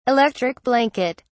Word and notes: electric blanket: British English

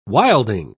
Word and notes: wilding: British English

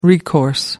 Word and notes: recourse: American English

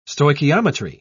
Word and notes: stoichiometry: British English

